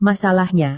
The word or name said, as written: masalahnya